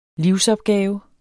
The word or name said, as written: livsopgave